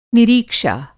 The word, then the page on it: निरीक्षा: Hindi